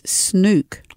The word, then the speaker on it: Snook: We've been saying her name incorrectly. – Sarah? – The whole time. It's Snook. – Snook? – Yeah, I heard one person once saying this. I thought, oh, they've got that wrong. What an idiot. Apparently that's how you say it.